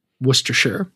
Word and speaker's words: Worcestershire: The correct pronunciation of Worcestershire. And I do believe that is correct. – Yes. – Worcestershire.